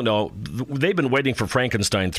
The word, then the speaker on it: Frankenstein: No, it's pronounced Frankenstein. – Okay, Dean Koontz, you give us the answer. Frankenstein or Frankenstein? – It's Frankenstein.